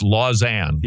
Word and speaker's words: Lausanne: L-A-U-S-S-A-N-E. I've never known how to, I thought maybe it was some sort of French pronoun, Lausanne or something. It's Lausanne.